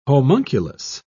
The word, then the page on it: homunculus: British English